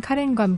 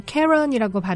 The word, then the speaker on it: Karen: 그러다가 4살 아래인 여동생 카렌과 함께 악단을 결성했는데요. 카렌, K-A-R-E-N, 미국에서는 뭐 캐런이라고 발음을 하는데 이 카렌이 드럼과 노래를, 리처드는 피아노와 화음을 담당했습니다.